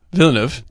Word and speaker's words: Villeneuve: Villeneuve, I believe is how it's pronounced. – I don't know. I don't fucking know. I'm bad with names. – Villeneuve. – Denis Villeneuve. – So I said it correctly.